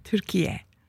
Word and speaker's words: Türkiye: So tell me how we say it now. – Türkiye. – Türkiye. – Mm-hmm. – It's like Y-E on the end. – Mm-hmm. – And but you don't spell it differently. – You do. – You do spell it differently. – T-U-R-K-I-Y-E.